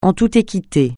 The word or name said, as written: en toute équité